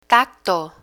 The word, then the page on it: tacto: Spanish